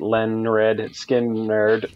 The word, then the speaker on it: Lynyrd Skynyrd: Number three, Lynyrd Skynyrd, pronounced Leonard Skinnerd, uh, 1973.